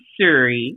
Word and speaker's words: Surry: It's like, I'm Surry. – I kept thinking it's not Siri, it's Surry.